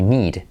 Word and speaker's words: need: Eh, por ejemplo, mm, necesito I need. Es de una doble E y es una I, need.